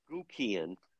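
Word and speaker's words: Guckian: He has a final debate with – he's the Republican candidate, and I even learned how to pronounce his last name with that new commercial. Aaron – Gukian.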